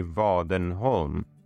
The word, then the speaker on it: Wadenholm: So I'm from Sweden, and we pronounce things a little bit differently in Sweden from in English or certainly other parts of the world. So my name, my surname is actually Wadenholm, which written in English or American pronunciation would be Wadenholm or Wadenholm or something like that. And so Wadenholm didn't work real great in Thailand. It would be like Wadenholm.